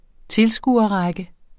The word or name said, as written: tilskuerrække